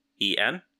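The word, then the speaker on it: Kwaidan: You say Quiet On? – Yeah, with a K. So it's Kwaiden. – Okay, I was close.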